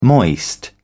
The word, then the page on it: moist: British English